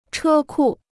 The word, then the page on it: 车库: chē kù